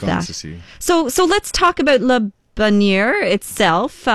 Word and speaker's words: Le Bonheur: Yeah, I think the South African culture, you know, speaking Afrikaans, we kind of pronounce it the way we read it. – Okay. – And that would be Le Bonheur.